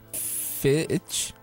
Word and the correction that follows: Finch: Finch? – Finch.